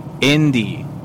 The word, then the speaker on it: indie: Well, I can't take that Indian music any longer. – Indie. – Huh? – You said it wrong. It's indie music.